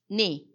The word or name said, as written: ni